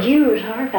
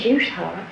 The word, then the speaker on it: Jew's harp: Jew's harp, I think, is correct. They call it juice harp.